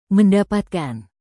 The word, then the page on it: mendapatkan: indonesian